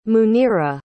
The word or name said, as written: muneera